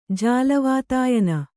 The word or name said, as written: ಜಾಲವಾತಾಯನ